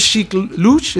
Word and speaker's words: Sheik Louch: It's features Sheik Louch. Is that how you say his name? – Yeah.